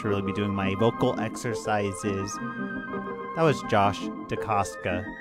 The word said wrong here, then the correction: Josh DeCasta: Josh DeCasta. There we go. That's how you say it.